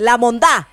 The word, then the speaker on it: La Mondá: No, tienes que decirlo bien. La Mondá. – Mondá. – Maldita sea, nunca todo. – Dilo. Con acento en la A.